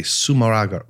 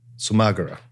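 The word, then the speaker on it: Zumárraga: Yeah, that was Bishop Fray Juan de Sumaragara. Sumagara. – Again, perfect pronunciation.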